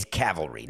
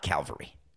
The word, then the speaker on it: cavalry: It's cavalry, not Calvary.